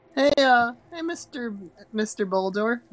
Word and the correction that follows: Bodar: His name's Bodar. – Boldar. – There's no L.